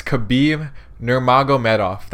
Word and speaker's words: Nurmagomedov: It's Khabib Nurmagomedov. That's how you pronounce it.